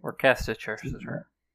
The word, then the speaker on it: Worcestershire: It's Worcestershire. – How do you actually say it? It's Worcestershire. – Worcestershire sauce. – But honestly, who fucking came up with that word? – Worcestershire.